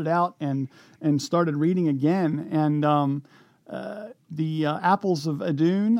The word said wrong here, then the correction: Eden: I guess I pronounced that correctly. – Eden, Apples of Eden.